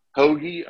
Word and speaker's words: Hoge: My main one, though, to specifically name that's lower is Tom Hoge, Hoagie, I believe it's pronounced.